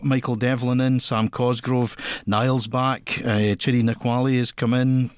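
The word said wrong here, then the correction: Nwakali: Sorry, Wakali, you've got to drop indeed the N when you pronounce it.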